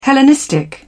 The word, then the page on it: hellenistic: British English